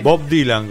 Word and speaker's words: Bob Dylan: ¿Bob Dylan o Bob Dylan? – Bob Dylan, como se debe pronunciar.